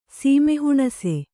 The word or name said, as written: ಸೀಮೆಹುಣಸೆ